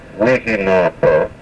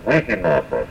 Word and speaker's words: Wihinapa: You notice that the accent there is after the first syllable, although the word has four syllables in it, Wihinapa. The accent here is after the first syllable, Wihinapa. You've got to say it that way in order to have it understood to mean sunrise.